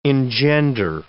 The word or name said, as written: engender